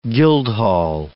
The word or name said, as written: guildhall